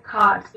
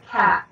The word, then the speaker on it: khat: How do you pronounce kat or chat or what is the how did you learn to pronounce it i pronounce it cot the british tend to pronounce it cat